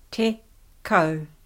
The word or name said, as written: tekau